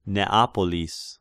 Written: Neapolis

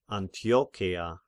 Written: Antiocheia

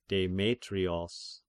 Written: Demetrios

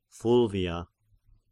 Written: Fulvia

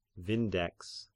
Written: Vindex

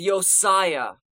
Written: Josiah

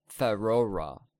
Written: Ferorah

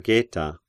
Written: Geta

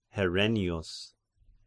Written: Herennius

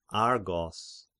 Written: Argos

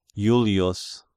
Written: Julius

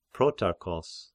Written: Protarchos

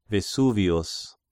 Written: Vesuvius

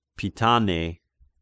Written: Pitane

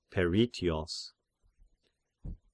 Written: Peritios

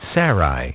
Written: Sarai

